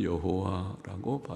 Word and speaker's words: 여호와: 학자들은 그 발음이 정확히 무엇인지 모른다고 하지만은 그러나 여하튼 그 히브리어 자음자 네 개의 글자 거기에 대한 모음자를 붙여놓은 것이 발음은 여호와라고 발음이 되는 모음자가 붙어있는 여호와라는 하나님의 이름 여호와 하나님께서는 온 세상 온 우주를 통치하신다 시편의 많은 구절에서 말씀하고 있는 바입니다.